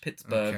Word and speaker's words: Pittsburgh: You're not pronouncing the H. Pittsburgh.